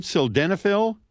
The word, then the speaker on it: sildenafil: And we mentioned briefly sildenafil, am I saying that right? Sildenafil? – Absolutely.